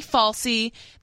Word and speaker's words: Fauci: He's also ready to deliver presents around the world after receiving his COVID-19 booster shot, according to Dr. Anthony Fauci, the nation's leading infectious disease mass murderer.